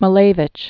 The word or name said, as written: Kasimir Malevich